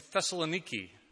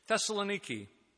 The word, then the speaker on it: Thessaloniki: Today, we're beginning a new sermon series that focuses on Paul's first letter to the church in Thessaloniki. I got it on good word from the Emanatides that that's the pronunciation that we need to go with.